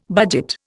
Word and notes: budget: Indian

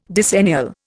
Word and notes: decennial: Indian

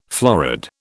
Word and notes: florid: British English

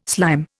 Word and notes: slime: Indian

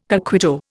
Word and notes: coquito: Indian

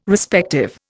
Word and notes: Respective: Indian

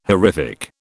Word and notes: horrific: British English

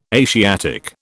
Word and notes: Asiatic: British English

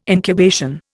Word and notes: incubation: Indian

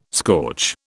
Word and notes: scorch: British English

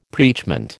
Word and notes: preachment: American English

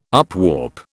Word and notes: upwarp: British English

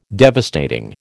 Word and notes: devastating: American English

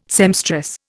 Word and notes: seamstress: Indian

